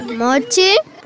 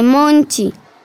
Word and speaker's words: monchi: Profe, ¿mochi? – Profe Natalia, no se dice mochi, se dice monchi. Así me enseñó mi abuelita.